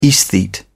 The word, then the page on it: esthete: British English